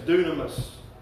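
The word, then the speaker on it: dunamis (δύναμις): It's a word that's spelled D-U-N-I-M-A-S, dunamis.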